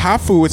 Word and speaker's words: Hafu: It's pronounced Hafu. It's H-A-F-U.